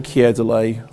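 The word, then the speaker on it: Keir Dullea: I say Kier Delay, I use that pronunciation because Noel Coward was once said to have said, Kier Delay, gone tomorrow.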